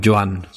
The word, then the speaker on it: Joan: No trying to pronounce, but for the listeners, how do you pronounce your first name? – Joan. – Joan. So it's Joan Luque.